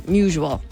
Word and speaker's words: Musial: Musial. – Like usual. – Usual, not musual.